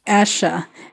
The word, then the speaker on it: Asha: is it pronounced Asha? – Asha.